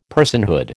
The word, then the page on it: personhood: American English